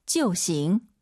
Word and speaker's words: 就行: 就行. – IU 사이에 오 넣어서 지어우, 就行 이렇게 암기하지 마시고 제가 이따 패턴, 꿀팁 알려드릴게요.